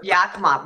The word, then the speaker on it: Yakima: I'm from Yakima, Washington, as you mentioned, and you said it correctly, which I'm just amazed at. people say Yakima, you know, all of this. So Yakima, wow, you nailed it.